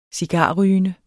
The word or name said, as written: cigarrygende